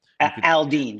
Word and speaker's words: Jason Aldean: Am I pronouncing it correct? – Aldean. Jason Aldean.